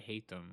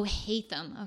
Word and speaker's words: Haytham: It's probably Haytham. I don't know. – Oh, Haytham. Okay, okay, okay. Oh, that's how you say his name.